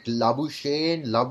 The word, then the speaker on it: Labuschagne: Is it Labuschagne? – No. Listen, look, I think the way to say this is Glenn Maxwell has been dropped and Australia have invented cricketers to replace him. Because Marnus Labuschagne cannot be a real name.